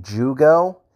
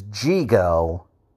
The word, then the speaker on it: Jigo: I just realized I've been saying Jugo. It's Jigo. I read it wrong and wrote it wrong in my notes, and that's why I've been saying it wrong, but it's actually Jigo.